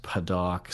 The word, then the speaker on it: Padauk: And I, I was listening to the, the it's pronounced Padauk. Somebody says Kind of, There's too many. There's a lot of weird pronunciations for that wood.